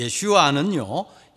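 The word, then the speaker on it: 예슈아 (ישוע): 이 예수의 정확한 히브리 발음은 예슈아입니다. 이 예슈아는요, 예호슈아라는 말 같습니다.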